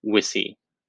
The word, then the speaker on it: wicca: So although pronounced differently, the modern term Wicca comes from the Old English word, which is spelled the same way, W-I-C-C-A, but it's pronounced Wissi. Hopefully I'm saying that correctly.